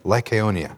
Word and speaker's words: Lycaonia: Lycaonia. – All right, there we go. Lystra, Derbe, and the cities of Lycaonia, and to the surrounding country, and there they continued to preach the gospel.